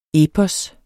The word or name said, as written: epos